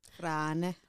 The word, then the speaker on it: Frane: Frane. – Yeah. Frane. – You need to sound a bit angry. – No, don't. – And Eastern European. Basically, with strong R. That's how you pronounce Frane.